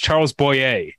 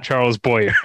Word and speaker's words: Charles Boyer: it's pronounced Charles Boyer, not Charles Boyer. I said Charles Boyer more than once.